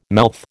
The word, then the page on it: mouth: American English